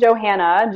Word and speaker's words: Johanna: I'm under the name Caitlin Johanna, J-O-H-A-N-N-A, and Caitlin is spelled C-A-I-T-L-I-N.